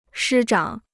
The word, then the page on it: 师长: shī zhǎng